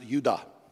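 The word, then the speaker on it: Yehudah: Judah in Hebrew is pronounced Yudah. But the word praise is the word Yada, Y-A-D-A-H.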